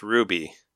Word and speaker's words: RWBY: RWBY, pronounced Ruby, is an American anime.